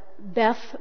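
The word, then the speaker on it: Bet (בית): The first part, Beth, which is pronounced Bet in Hebrew, means house.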